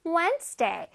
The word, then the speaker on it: Wednesday: 자, 저기서 D는 소리를 내지 않습니다. 오케이? 그냥 무늬만 D가 있는 거예요. 자, Wednesday. 근데 뜨 소리는 살짝 다. 봐봐. Wednesday.